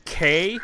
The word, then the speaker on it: Cay: And then a band called K, which is spelled C-A-Y, and their album is called Nature Creates Freaks.